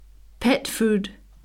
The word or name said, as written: pet food